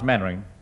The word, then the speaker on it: Mainwaring: You must be Mainwaring. – Oh, how do you do, Colonel? Actually, it's pronounced Mannering.